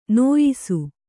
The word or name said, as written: ನ್ಯಾಸಿ